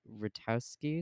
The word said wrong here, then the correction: Rutkoski: There's a second K. It's Rutkoski.